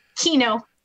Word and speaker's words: Kenow: Keenow. – Keenow. K-E-N-O. Yep. .com. Mm-hmm. – K-E-N-O-W. – K-E-N-O-W.